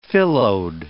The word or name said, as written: phyllode